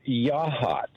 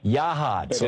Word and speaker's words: Yachats: It's pronounced Yahats. – Yahats. Okay, thank you very much. – But it's spelled like Yachats.